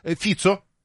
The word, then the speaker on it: Фицо: Ваше сообщение читаем, Игорь, 650-й, Фицо, ударение на первый слог, но при этом почему-то подчеркнул букву «О».